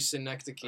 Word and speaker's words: Synecdoche: What other works of Charlie Kaufman have you seen besides just Schenectady and Anomalisa? – It's actually Synecdoche. It's a pun.